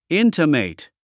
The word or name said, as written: intimate